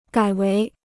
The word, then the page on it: 改为: gǎi wéi